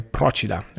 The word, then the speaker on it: Procida: Draft thoughts on Gabriele Procida, or Gabriele Procida, which is the way in which we pronounce it.